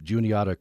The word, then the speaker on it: Juniata: You did a podcast with some folks at Juniata College. Is that how you pronounce that? – Yeah. – Juniata College. I'm not sure where that is. – Juniata is one of our wonderful partner campuses, and they're in Pennsylvania.